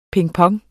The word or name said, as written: pingpong